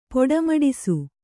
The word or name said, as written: ಪೊಡೆಮಡಿಸು